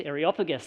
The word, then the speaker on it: Areopagus: Areopagus, that's how you say it.